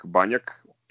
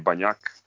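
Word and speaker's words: Bagnack: U pitanju je Maki, ali ne Mačo, nego Frank Banjak ili Banjak, kako ga akcentuju, uh, momak iz Kameruna, zvanično šesti Kamerunac u Partizanu, proverio sam, napunio 25 godina pre par nedelja.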